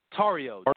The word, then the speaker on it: Tarrio: How do you say his last name? – Tarrio.